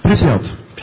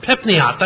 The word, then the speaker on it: Pripyat: Pripyat. – Pripyat, that's how you pronounce it.